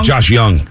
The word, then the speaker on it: Josh Jung: Is it Josh Jung? Who's the guy who's Josh Young. Josh Young. – Am I saying it wrong? – Yeah, Josh Young. – I thought it was Jung. Okay. – Jay is silent.